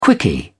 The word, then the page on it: quickie: British English